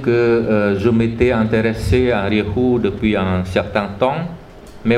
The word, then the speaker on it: Rahu: C'est en sanskrit, c'est Rahu, mais en khmer, ça se prononce Rihu.